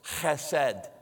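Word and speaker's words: hesed (חסד): But actually, the way that they pronounce it, we don't have this consonant sound in our language, but it's kind of fun to say. It sounds like you're clearing your throat. It's hesed, hesed.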